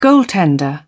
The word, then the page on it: goaltender: British English